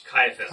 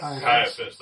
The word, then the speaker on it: Caiaphas: Caiaphas. – Caiaphas, the high priest, had advised the Jews that it would be expedient that one man die for the people.